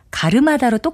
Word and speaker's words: 갈음하다: 특히 가름하다와 갈음하다는 제가 글자로 쓰는 대로 발음해서 말을 했지만 실제는 가르마다로 똑같이 발음되기 때문에 혼동하는 일이 상당히 많습니다.